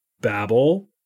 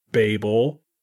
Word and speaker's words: Babel: And today we are going to talk about a story from the Bible, the Tower of Babel, Babel, Babel, Babel, Babel. However it is that you say it. We actually had a little discussion before the podcast about how do you say this. And then we decided, well, we'll just look it up and I just gave you the four accepted uh, pronunciations.